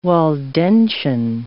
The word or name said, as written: Waldensian